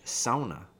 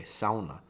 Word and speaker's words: sauna: I worked on cutting, I'm building a Finnish sauna. You gotta pronounce it the right way. Sauna. Not sauna. Sauna.